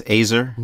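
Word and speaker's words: ezer: Yes, azer. – Mm-hmm. Okay. Yeah. I always remember it because it rhymes with razor.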